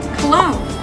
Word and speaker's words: cologne: El siguiente ejemplo se da en la palabra cologne. sin pronunciar la G. Cologne.